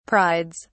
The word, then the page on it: prides: British English